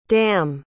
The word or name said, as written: damh